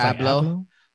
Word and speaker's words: Abloh: I think it's Abloh. Like Abloh.